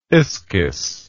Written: ischys

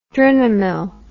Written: drinamyl

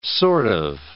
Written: sort of

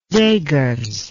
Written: Seghers

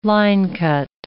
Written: linecut

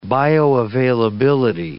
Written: bioavailability

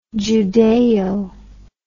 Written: Judaeo

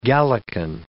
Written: Gallican

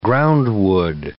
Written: groundwood

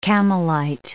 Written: Campbellite

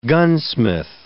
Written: gunsmith

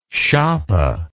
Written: schappe

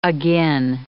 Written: agin

